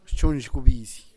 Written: xtunxhgubis

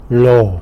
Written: lo

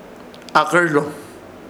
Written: AHKƗR

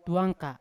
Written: tuanka

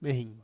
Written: mehin

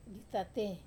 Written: atate

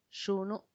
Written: xunu

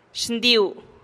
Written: xndiu

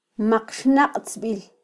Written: maqxnaqtzbil

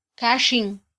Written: kaxin

